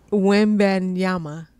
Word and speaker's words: Wembanyama: Victor Wembanyama. Is that how you say it? – Yes. Hey, I've been drinking. – It's your story. Wembanyama.